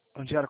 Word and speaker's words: Andiara: Eu precisava falar com a Andiara, é isso mesmo? É assim que pronuncia? – É ela. – Andiara, como você está?